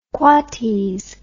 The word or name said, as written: quoties